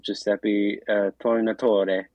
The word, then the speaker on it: Giuseppe Tornatore: by it's a 1988 film by Giuseppe Tornatore.